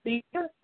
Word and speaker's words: cedar: What animal moved its tail like a setter? What animal moved its tail like a setter? Am I pronouncing that right? Setter, cedar, C E D A R. A cedar. Cedar.